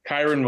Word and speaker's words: Kyren: Am I saying his name right? Kyren? – Kyren Williams, yeah.